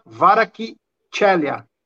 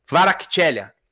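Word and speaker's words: Kvaratskhelia: Só para te falar, o Júlio Pindanga mandou, se pronuncia Varakchelia. – Varakchelia.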